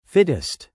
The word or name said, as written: fittest